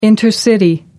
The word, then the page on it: intercity: American English